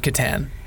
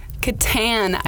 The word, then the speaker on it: Catan: Settlers of Catan. – Catan. Yeah. I wouldn't have. – What was your first guess? What were you going to say? – It wasn't that, though. Okay, so Settlers of Catan.